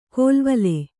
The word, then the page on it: ಕೋಲ್ವಲೆ: kōlvale